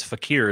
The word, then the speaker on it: fakir: John MC says, Fakir is the right pronunciation.